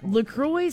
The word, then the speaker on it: LaCroix: It's a cross between La Crosse, Wisconsin, where it's made, and the St. Croix River in western Wisconsin. So it's pronounced LaCroix.